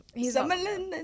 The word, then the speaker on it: Somerhalder: Ian Somerhalder. I don't have him on there, Ian Somerhalder. – Yeah, I don't know how to say his last name. – He's Somerhalder. – Somerhalder.